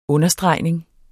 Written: UNDERSTREGNINGER

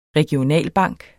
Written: REGIONALBANK

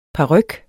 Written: paryk

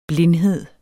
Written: blindhed

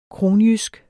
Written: kronjysk

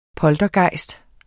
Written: poltergejst

